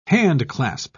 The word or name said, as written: handclasp